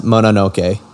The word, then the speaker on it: Mononoke: I watched Princess Mononoke.